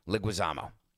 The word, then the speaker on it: Leguizamo: Leguizamo.